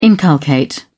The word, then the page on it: inculcate: British English